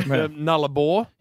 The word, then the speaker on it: Nullarbor: What is the correct pronunciation? – The pronunciation. Yeah. Nullarbor. – You sure? – Yes. It's not a ball.